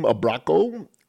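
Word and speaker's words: Abraço: And this particular one, the song means, it's pronounced Abraco. And it means a big hug.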